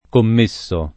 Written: commesso

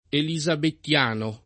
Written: elisabettiano

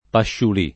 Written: pasciulì

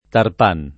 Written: tarpan

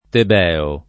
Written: tebeo

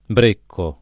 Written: brecco

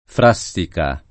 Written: Frassica

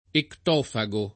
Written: ectofago

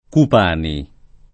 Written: Cupani

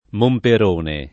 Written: Momperone

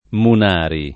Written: Munari

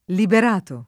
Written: Liberato

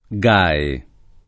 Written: Gae

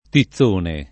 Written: tizzone

